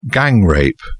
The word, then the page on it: gang-rape: British English